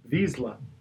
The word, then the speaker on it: Vizsla: Vizsla.